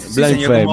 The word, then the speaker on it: Blind Faith: Bueno, y ahora estamos escuchando de fondo, ya que estamos ahí por los 70, hubo una banda que se llamó Blind Faith. Lo pronuncié, sí, más señor, o menos. – Blind Faith, muy bien.